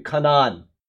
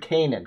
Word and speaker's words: Canaan: You've got an A in Hebrew, that's ah, right? So Canaan, the word Canaan, Canaan, okay?